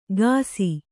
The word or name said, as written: ಘಾಸಿ